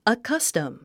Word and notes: accustom: əkʌ’stəm; アカスタム

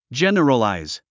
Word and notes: generalize: dʒén ə rəlàiz; ジェネラライズ

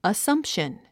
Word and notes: assumption: əsʌ́mpʃən; アッサンプション

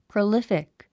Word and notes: prolific: prəlífik; プロリフィック

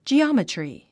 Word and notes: geometry: dʒiάmətri; ジオマトリィ

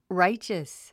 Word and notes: righteous: ráitʃəs; ライチャス

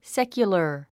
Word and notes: secular: sékjulə r; セキュラァ

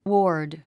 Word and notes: ward: wɔ́ːrd; ウォード

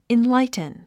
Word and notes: enlighten: inláitn; インラァイトゥン

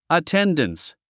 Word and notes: attendance: əténdəns; アテンダンス

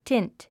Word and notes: tint: tínt; チント

